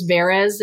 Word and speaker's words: Veres: But the thing that when I started thinking about writing about the Witch of Del Rey, her name's Rose Veres, as I pronounce it, V-E-R-E-S.